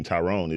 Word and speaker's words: Tyron: Like, yeah, I mean, most people that pronounce their name Tyrone is T-Y-R-O-N-E. My name is T-Y-R-O-N.